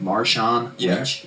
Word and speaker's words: Marshawn Lynch: You know, am I saying that right? Marshawn Lynch? – Yeah, correct.